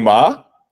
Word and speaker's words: Maher: Bill Maher.